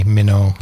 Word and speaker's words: Mino: No, it's Minnow. They Minnow. Made it Minnow. But they didn't spell it like M-I-N-N-O-W. They spelled it M-I-N-O, so people would say Minnow.